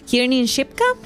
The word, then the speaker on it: Kiernan Shipka: Kiernan Shipka.